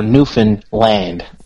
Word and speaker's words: Newfoundland: So think about goofing, like goofing off. So think of that word goofing. Now put in Newfin. So we're Newfoundland.